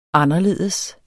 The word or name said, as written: anderledes